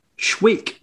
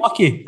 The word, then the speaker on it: Schwacke: So you're saying that, though, but from what I did see, I thought, now I'm probably going to mispronounce this, I presumed it was Brian Schweik, but it sounded like on the highlights you're pronouncing it Schwacke. Is that right? – Schwacke. Aye, that's Schwacke. I thought it was Schweik as well.